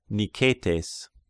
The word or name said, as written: Niketes